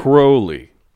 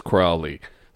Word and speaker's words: Crowley: Crowley. But Ozzy says Crowley. So we're just going to say either or.